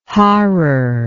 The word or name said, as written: harar